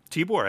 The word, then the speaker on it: Tibor: Tibor Takacs? – Tibor, I think, is correct.